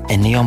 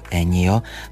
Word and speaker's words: Ennio: Ennio Morricone, někdy slyšíme jeho jméno vyslovovat i jako Ennio, složil hudbu k více než 500 snímků.